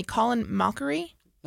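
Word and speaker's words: Colin Mochrie: I'm gonna say his name wrong. You're gonna have to help me. Colin Mockery. – No, you got it right.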